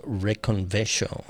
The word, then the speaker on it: Reconvexo: And so I absolutely had to grab this album, their new album that's titled Reconvexo, I believe is how you pronounce it. R-E-C-O-N-V-E-X-O, Reconvexo, I think.